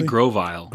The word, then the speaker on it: Grovyle: A Grovyle? Is that how you pronounce it? – I say Grovyle.